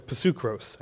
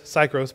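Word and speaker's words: psukros (ψυχρός): The next word is psukros. I know it says psychros, but in Greek the Y is pronounced as a U, and you pronounce the P. There are no silent Ps.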